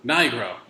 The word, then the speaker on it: Nigro: How do you pronounce it? – Nigro. Yeah. But it's cooler when you say it Negro.